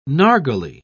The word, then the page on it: nargileh: British English